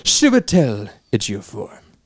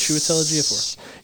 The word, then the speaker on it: Chiwetel Ejiofor: I'm going to go with, and I will never, ever pronounce this name correctly, but I love this man, Chiwetel Ejiofor. – That was actually right. Yes. Chiwetel Ejiofor.